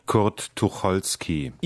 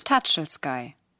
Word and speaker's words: Tucholsky: Kurt Tucholsky. – Ihr macht daraus Tatschelsky. Tatschelsky. Kurt Tatschelsky ist ein Superhit von Kanye West.